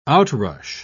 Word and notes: outrush: British English

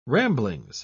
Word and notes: ramblings: British English

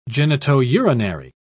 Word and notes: genitourinary: British English

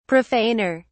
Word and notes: profaner: British English

